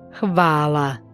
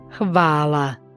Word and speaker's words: hvala: Das H im Kroatischen klingt wie ein weiches CH, ähnlich dem CH im deutschen Wort Nacht. Ein Beispiel dafür ist Hvala, was Danke heißt. – Hvala. – Danke. – Hvala.